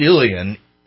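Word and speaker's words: uilleann: It's pronounced Ilian. It's U-I-L-L-E-A-N-N.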